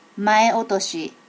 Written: mae otoshi

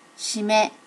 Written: shime

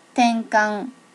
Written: tenkan

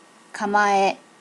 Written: kamae

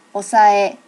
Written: osae

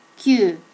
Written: ku

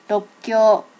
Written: rokkyo

